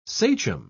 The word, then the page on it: sachem: British English